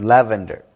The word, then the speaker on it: lavender: Not lavender. Lavender. – Lavender. – But the accent goes on the first syllable.